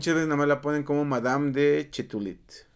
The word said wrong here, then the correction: Châtelet: Ok. – Chatelet.